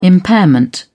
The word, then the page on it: impairment: British English